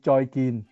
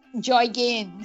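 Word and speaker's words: Joy Gin (再見): And is it Zai Ji Yan? Is that goodbye in Vietnamese? How would you say that? – In Cantonese, it means Joy Gin. – Joy Gin.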